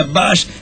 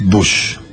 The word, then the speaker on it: Bush: A la derecha, analiza Bush, nacionalizar hipotecas para frenar la crisis. – Perdón, es Bush. El, guarda que la sobrepronunciación también es mala, ¿eh? – Analiza Bush.